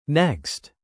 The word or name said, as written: next